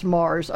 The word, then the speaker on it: Moers: It's spelled M-O-E-R-S, but it's pronounced Mars, I guess, better than, you know, some places in Pennsylvania.